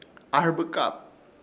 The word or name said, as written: AHƗRBƗKAP